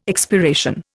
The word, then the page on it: expiration: Indian